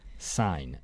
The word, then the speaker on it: sign: sign。 S-I-G-N 那个 G 不要发音 sign